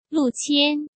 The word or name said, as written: Lu Qian